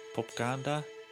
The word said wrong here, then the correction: pagoda: I'm going to look up how to pronounce that. Pagoda. It's just Pagoda. I was saying too many letters.